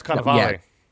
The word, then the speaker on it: Cannavale: I like how you pronounce him like some sort of Italian cured meat. – That's how you pronounce his name. – I've always heard it. – You say Cannavale? – No, it's Cannavale.